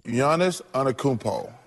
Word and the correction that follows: Giannis Antetokounmpo: And you're leaving a syllable out, but it's a good effort. – Oh, the Greek freak. – Antetokounmpo.